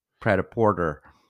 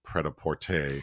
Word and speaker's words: Prêt-à-Porter: Oh, and I said I used my 10-year-old's pronunciation of Prada Porter. – So there's an Altman film, Ready to Wear, but the French title is Prêt-à-Porter, and yes.